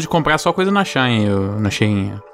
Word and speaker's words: Shein: Se bem que ele já estava envolvido num rolê aí, a treta da Shein, que é o nome certo de se pronunciar. Shein.